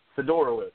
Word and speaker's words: Fiedorowicz: I may not pronounce his name correctly. Fiedorowicz. – Fiedorowicz, Osweiler. – Yeah, I believe that's what it is. – What is it? – Fiedorowicz. – Fiedorowicz. Okay, there we go, yeah.